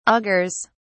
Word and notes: augurs: British English

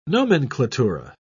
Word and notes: nomenklatura: British English

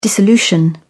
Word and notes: dissolution: British English

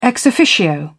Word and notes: ex officio: British English

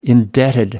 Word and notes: indebted: American English

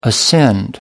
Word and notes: ascend: American English

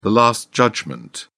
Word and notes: last judgment: British English

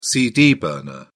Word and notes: cd burner: British English

